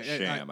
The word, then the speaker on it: Cham: C-H-A-M, Cham. – Yeah, Cham. Okay. – Sham. I think they pronounce it Sham.